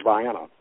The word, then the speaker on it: Vienna: If I give you the name Vienna, how would you pronounce that? – Vienna. – And see, not in the hill country. It's Vienna. And it's named for Vienna, Georgia.